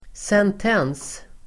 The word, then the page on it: sentens: [sent'en:s]